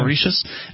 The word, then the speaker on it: Mauritius: Eight, I don't even know how you pronounce this, Mauritius? – I think you might have been right the first time. – Mauritius.